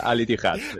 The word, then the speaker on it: Al Ittihad: No sé si lo he dicho bien, Al Ittihad, y si no, que me perdonen. Jesús Salvador, ¿qué tal? Buenas tardes. – Hola Álvaro, buenas tardes. No, lo has dicho fenomenal. Al Ittihad. – Al Ittihad.